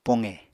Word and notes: poqe: Apatani

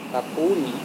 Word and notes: tapuni: Serewen

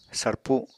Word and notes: sarpu: apatani

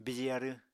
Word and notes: bije arw: Apatani

